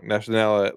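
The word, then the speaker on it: Nazionale: Until then, I believe that there was a single party in every country, and that in Italy, it was the Partito Nacionalist Fascista. I totally just said that wrong. Nacionale Fascista. I can't speak Italian, folks.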